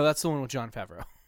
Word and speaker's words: Favreau: Favreau, is that how you pronounce his name? – Favreau, yeah.